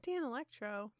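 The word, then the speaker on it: Danelectro: Dan Electro. – Yeah. No. I might be saying it wrong. But no, that's right.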